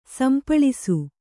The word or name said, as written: ಸಂಪಾಳಿಸು